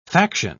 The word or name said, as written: faction